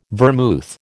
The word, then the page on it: vermouth: American English